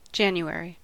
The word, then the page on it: January: American English; [ˈdʒæn.ju.ˌɛɹ.i]